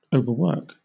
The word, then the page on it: overwork: British English